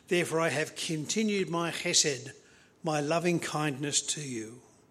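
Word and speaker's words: chesed (חסד): This word hesed, or to pronounce it properly, chesed, is most frequently found in the Psalms, but also comes in Hosea and in Jeremiah chapter 31.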